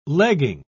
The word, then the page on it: legging: British English